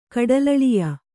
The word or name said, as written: ಕಡಲಳಿಯ